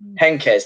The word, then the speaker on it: Heynckes: Heynckes, that's it.